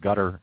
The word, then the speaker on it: Qatar: Qatar is pronounced Qatar in that particular dialect.